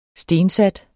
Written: stensat

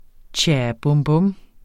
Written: bum

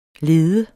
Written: ledes

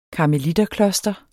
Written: karmeliterkloster

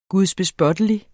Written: gudsbespottelig

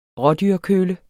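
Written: RÅDYRKØLLE

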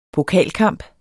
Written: pokalkamp

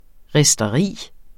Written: risteri

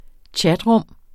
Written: chatrum

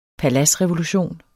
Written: PALADSREVOLUTIONENS

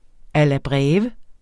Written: alla breve

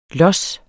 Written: los

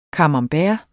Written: camembert

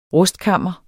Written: RUSTKAMMERS

